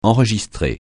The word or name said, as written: enregistrer